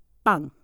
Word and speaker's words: 빵: Okay, so for Korean, bread in Korean is bang. Bang. It's a double consonant b sound, so it's a sang biup.